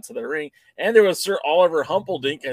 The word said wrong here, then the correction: Humperdink: You butchered that one. Big Daddy Dink is Sir Oliver Humperdink.